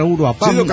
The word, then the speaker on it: Uruapan: Bueno, ahí les dejo un par de botellas de licor de changunga que me traje en la caja de mi tierra Uruapan. Acentúes en la última sílaba pirata, Uruapan, ok.